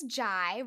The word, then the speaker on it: Jy: My name is Jai, rhymes with pie.